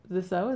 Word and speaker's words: Zissou: Zissou, is that really how you say it? – Mm-hmm.